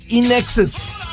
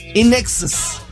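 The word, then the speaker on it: INXS: Es In Excess. Quiere decir como en exceso. Realmente, la pronunciación, mucha gente además dice In Excess. No, es In Excess.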